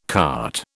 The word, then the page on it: cart: British English